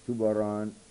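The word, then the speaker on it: Zurbarán: The picture I oftentimes throw on the screen here, one by Tubaran, it's spelled with Z, but they pronounce it T-H, and he's one of the artists that's in right now of some of the mod crowd.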